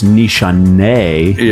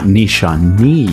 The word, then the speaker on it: Nishane: Actually, it's Nishane, not Nishane.